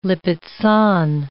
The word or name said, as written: Lipizzan